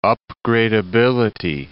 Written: upgradeability

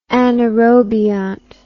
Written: anaerobiont

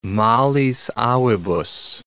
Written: malis avibus

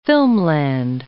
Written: filmland

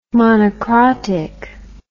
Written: monocrotic

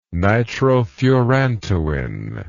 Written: nitrofurantoin

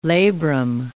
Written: labrum

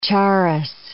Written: charas

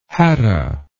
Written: hatta

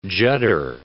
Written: judder

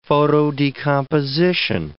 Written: photodecomposition

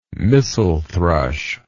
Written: mistle thrush

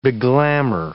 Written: beglamor

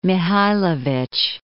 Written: Mihajlovic

